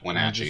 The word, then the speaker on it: Wenatchee: Wait, Wenatchee? Is that how you say it? – Yeah. Yeah, Wenatchee.